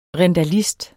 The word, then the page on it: rindalist: ʁεndaˈlisd